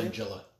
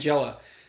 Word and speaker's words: Langella: How do you say his Langella. – Name? Langella.